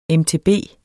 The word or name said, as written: mtb